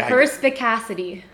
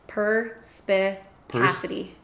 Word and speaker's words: perspicacity: Okay, perspicacity. – Walk me through that, through syllables. Perspicacity. Perspicacity. – You got it.